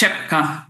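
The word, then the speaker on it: Rzepka: Genau, also mein Spiel ist Rezepka. T-S-C-H wird das gesprochen.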